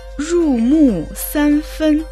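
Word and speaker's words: жу му сан фэн (入木三分): Слово «жу» произносится в 4-м тоне. Переводится как «пропитать», «проникать». Слово «му» произносится также в 4-м тоне. В переводе это «дерево». – Слово «сань» произносится в 1-м тоне. Это числительное 3. Слово «фэнь» произносится также в 1-м тоне. Это название меры длины. В данном контексте «сань фэнь» равно 1 сантиметру.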